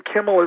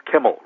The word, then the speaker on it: Kimmel: The A stands for Alan, A-L-A-N, and Kimmel is pronounced just as it looks. Kimmel rhymes with Himmel.